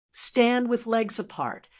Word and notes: stand with legs apart: American English

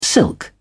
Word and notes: silk: British English

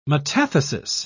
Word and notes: metathesis: British English

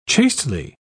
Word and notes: chastely: British English

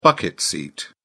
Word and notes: bucket seat: British English

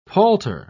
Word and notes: palter: British English

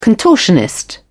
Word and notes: contortionist: British English

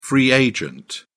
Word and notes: free agent: British English

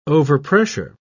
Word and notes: overpressure: British English

